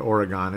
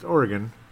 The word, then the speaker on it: Oregon: And I'm saying it correctly, unlike most people, they call it Oregon. It's Oregon. Yes, because I've met people from Oregon, and that's what they say.